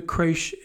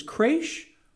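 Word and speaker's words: crèche: And in many ways with this, the creche is one of those. And it's, I also found that it's either pronounced as creche or creche. – Could be either. – Can be pronounced either way.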